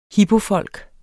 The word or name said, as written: hipofolk